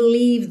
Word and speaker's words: leave: Só lembre sempre de pronunciar essa palavra leave mais horizontalzinha, leave mais cheia, mais gordinha, para ela ficar diferente de live, que é o morar ou viver, L-I-V-E, ok?